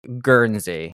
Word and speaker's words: Guernsey: So first question for you is, you live in Guernsey.